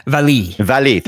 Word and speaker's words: Vallée: by heart attack of director Jean-Marc Vallée. How do you say his name? – Vallée. – Thank you very much.